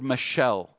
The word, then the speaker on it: mashal: In the Old Testament, the Hebrew word that is translated parable, and it's probably the word that Jesus uses, is the word mashal. If you think of like a girl named Michelle, all right, that's how it's pronounced, Michelle.